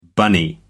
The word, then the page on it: bunny: American English